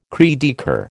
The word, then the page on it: cri de coeur: American English